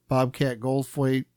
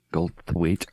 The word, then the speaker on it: Goldthwait: Bobcat Goldflake. How do you say his name? – It's close enough. – Goldflake. – Goldthwait.